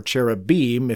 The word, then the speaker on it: cherubim: or cherubim, if you want it to rhyme with Elohim.